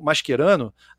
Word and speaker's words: Mascherano: A gente sabe que no italiano uh, o CH tem a pronúncia do nosso QU, né? E um exemplo que aonde a gente, eu falei que o mascherano, a nossa pronúncia, né, do português brasileiro aqui mais consolidado, é, é ela está mais, muito mais próxima do italiano do que a dos argentinos, né?